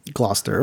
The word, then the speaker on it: Gloucester: And then there's Gloucester. – Gloucester. Okay. – That's spelled Glou. C-E-S-T-E-R.